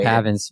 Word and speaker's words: Pavin: The next guy is going to be Paven Smith. Paven I think it's Pavin. I think it's Pavin.